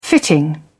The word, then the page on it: fitting: British English